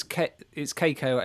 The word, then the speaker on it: Keiko: And I said, P.S., it's Keiko as in K-A-Y, because she spelt K-E-I-K-O. I said, it's spelt Keiko as in K-A-Y-K-O. That's right. That's how you pronounce it.